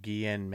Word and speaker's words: Ghislaine: Oh, Ghislaine Maxwell. – That's how you say the name? – Yeah. – We just got a instant, pronunciation check on us right now.